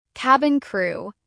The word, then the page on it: cabin crew: American English